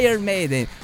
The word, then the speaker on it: Iron Maiden: É, a pronúncia é essa mesmo. Iron Maiden.